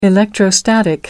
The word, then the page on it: electrostatic: American English